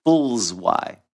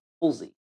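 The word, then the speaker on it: Fullyz: He did it backward. He did fools Y. – That's what I did. No, it's pronounced. It's pronounced Fullsy.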